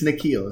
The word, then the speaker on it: N'Keal: It's N'Keal. It's pretty easy.